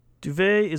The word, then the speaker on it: duvet: Duvet is originally a French word, and it's retained its silent T in English, being pronounced duvet.